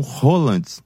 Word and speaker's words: Haaland: E agora, por conta do jogo do Brasil, depois no domingo, depois da perda, a Luísa Sonza começou a ser comparada com Haaland. – Vale qualquer pronúncia. – Mas dizem que... – É que a pronúncia é essa mesmo.